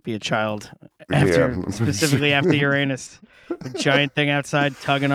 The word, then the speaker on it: Uranus: So I mean, it's like an old Greek word, and I think that's the correct pronunciation. It's just, you know, sounds very much like something else. – Yeah, like, so people are out here planet shaming because it's saying Uranus.